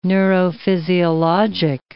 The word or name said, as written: neurophysiologic